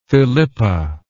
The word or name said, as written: Philippa